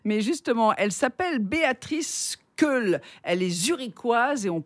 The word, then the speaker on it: Keul: Comment K-E-U. K-E-U-L. Alors, vous qui êtes... – Ah, il y a Keul.